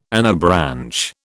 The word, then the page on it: anabranch: British English